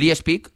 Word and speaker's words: Leipzig: Leipzig, ¿no? ¿Cómo se dice? – Leipzig. – Ay, perdonar. – No, como Sauquillo. – Aquí son muy puristas, ¿eh? – Sauquillo dice es que, Leipzig, perdonad.